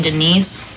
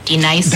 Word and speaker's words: D-Nice: Do you mean Denise? – Son of a bitch. You say your name right now. – Denise? – Say it right. – Denise. – Correctly. – Denise. – Right. – Denise. – Right. – D-Nice. – That's better. Thank you.